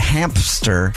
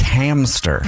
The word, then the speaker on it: hamster: It's not hamster. It's hamster. – Yeah. – Like a ham. And stir. I've been mispronouncing that my whole life. Hamster. There's no P in hamster.